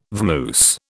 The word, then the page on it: vamoose: British English